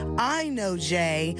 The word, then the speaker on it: INOJ: Up next is I Know Jay, Love You Down, otherwise known as I-N-O-J, because that's how I used to say her name.